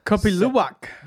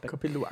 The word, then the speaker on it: Kopi Luwak: Here is the how do you pronounce it again? The poo-poo coffee. – Lupak. Oh, Kopi Luwak. – That's it. That's it. – Kopi Luwak.